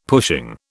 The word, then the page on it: pushing: British English